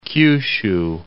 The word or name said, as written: Kyushu